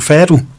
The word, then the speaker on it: fado: Altså genren fado? – Fado.